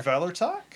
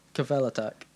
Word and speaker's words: Kvelertak: K-V-E-L-E-R. – Kvelertak? – T-A-K. – Kveletak. – Kveletak. – Oh, we'll just go for Kveletak. They're Norwegian, so that's probably how you say it.